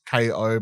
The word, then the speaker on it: Kao: You know, it's like uh, this K- Kao the kangaroo, like pronounced KO, but spelled K A O.